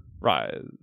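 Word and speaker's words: Ries: Rise. Rise? – They say it like it's Liz. They say it so much like it's Liz, which is interesting. – Or like raspberry. Like rise. It's rise.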